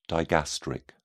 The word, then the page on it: digastric: British English